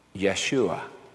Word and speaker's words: Yeshua: In Hebrew, the word salvation is pronounced like this. Yeshua.